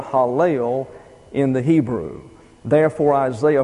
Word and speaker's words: Helel: That's H-A-L-E-L. Oh, I'm sorry. H-E-L-E-L. Pronounced Hallel.